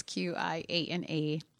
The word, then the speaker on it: Qiana: Most people don't expect it to be spelled with a Q and with a Q without a U, but it's Qiana and then Hicks, Hicks.